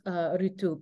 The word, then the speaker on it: Ritu: Thank you for also explaining how to pronounce Ritu, because it's spelled on the cover R-T-U. So you miss the as a Westerner.